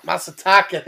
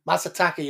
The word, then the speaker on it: Masataka: Masataka, thank you. I was going to say Masashiro, and I would have butchered it. Masataka Yoshida.